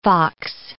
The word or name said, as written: fox